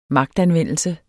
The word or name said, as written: magtanvendelse